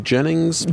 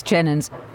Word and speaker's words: Jennens: Tell me about Charles Jennens, or some people say Jennings, but it's spelled E-N-S. – Yes, it's spelled E-N-S, Jennens, and usually these days we say Charles Jennens.